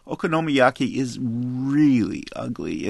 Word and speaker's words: okonomiyaki: I want to begin with a review of a restaurant that serves a dish called okonomiyaki.